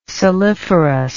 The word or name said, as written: saliferous